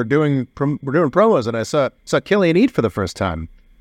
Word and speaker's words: Cillian: Oh, Cillian Murphy. – Cillian Murphy.